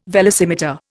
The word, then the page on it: velocimeter: Indian